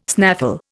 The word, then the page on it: snaffle: Indian